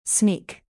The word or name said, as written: sneak